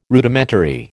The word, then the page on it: Rudimentary: American English